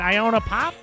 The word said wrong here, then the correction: Icona Pop: I think it's Icona. – Icona Pop.